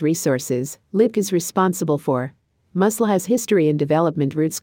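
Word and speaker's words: musl: Muscle, pronounced like the word muscle or muscle,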